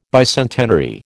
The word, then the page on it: bicentenary: American English